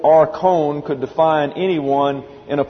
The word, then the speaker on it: archon: A-R-C-H-O-N, pronounced archon.